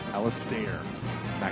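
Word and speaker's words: Alasdair MacIntyre: I'll be very close to Alistair McIntyre. – Yeah, say his name correctly. Say his name correctly. Alistair McIntyre. – Alistair McIntyre.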